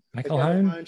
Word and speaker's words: McElhone: McElhone.